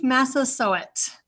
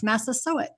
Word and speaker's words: Massasoit: For the grandparents and parents and kids that are listening, just say sew it, like sew it up. It's Massasoit. That's an easy way to remember how to pronounce his name.